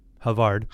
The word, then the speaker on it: Havard: It says, uh, in his book, Virtuous Leadership, Alexander, how do you pronounce his last name? Havard? Havard.